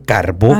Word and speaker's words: Carbó: Acento en la O. – Carbó. – Sí, porque muchas, muchas personas, principalmente del sur de la República, lo mencionan como carbo.